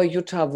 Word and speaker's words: Jutta: Oh, you can find me under Elements of Birth or Jutta Wohlrab. both things. Instagram is Jutta Wohlrab.